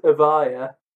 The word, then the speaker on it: Evija: It's apparently pronounced the Lotus Evija, depending on where you're from.